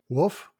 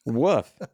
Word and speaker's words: wolf: How do people from the Midwest pronounce it? – Wolf. – Wolf. Woof. They say woof. They would say woof in the fold. And they don't say woof in the fold. They pronounce the L in the fold. Something about that word, wolf. It is pronounced woof.